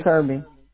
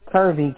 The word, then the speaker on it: Kirby: Ozzy Kirby. – Can you spell it for me? – Ozzie. Kirby, K-I-R-B-Y.